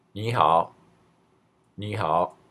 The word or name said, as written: nihao